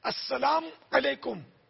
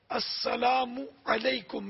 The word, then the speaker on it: السلام علیکم: السلام علیکم یعنی میم کو ساکن پڑا، پیش نہیں پڑا، تب بھی جواب واجب نہیں ہوگا. تو صحیح تلفظ ہے السلام علیکم، میم پر پیش ہے.